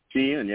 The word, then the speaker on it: Shein: So don't use Temu, and you said Shein? – Shein, yeah. It's spelled exactly the way it sounds. She and then N, you know.